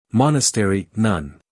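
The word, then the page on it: monastery: uk /ˈmɒnəst(ə)ri/; en-us /ˈmɑːnəsteri/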